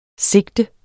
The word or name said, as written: sigte